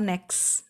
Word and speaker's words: next: Aí eu também posso usar next e next, a pronúncia do next. Muitas vezes eu não pronuncio o T eu posso pronunciar só next, next.